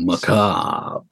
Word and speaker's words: macabre: I'm one of the people who doesn't pronounce the R Macabre. I'm just saying. Not everybody. – I believe okay, anyway. That's the correct way to say it. – Well, it's the French way of saying it.